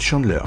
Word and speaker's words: Chandleyr: mon nom s'écrit Chandler, Chandler avec un Y.